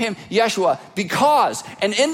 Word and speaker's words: Yeshua: That's the transliteration of the Hebrew word that comes from the word Joshua that's pronounced Yeshua.